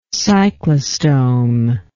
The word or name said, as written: cyclostome